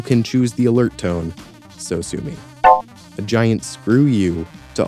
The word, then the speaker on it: Sosumi: So sue me, except spelled S-O-S-U-M-I, as though it were a Japanese word.